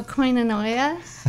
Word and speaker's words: koinonia: What is koinonia? Did I say that right? – Oh, koinonia. it is.